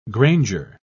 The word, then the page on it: granger: British English